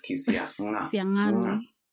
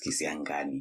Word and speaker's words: Kisiang'ani: My name is Emmanuel Kisiangani. The G has an apostrophe. – Yeah, I just... – So it's Kisiangani. But I just prefer just telling it's Kisiangani because the pronunciation tutorial is normally hard work.